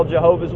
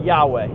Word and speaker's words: Yahweh: So that's, if you've ever heard Jehovah, that's where that comes from. It's a mispronunciation. And the best guess scholars have is that God's name was pronounced Yahweh.